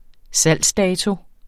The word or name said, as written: salgsdato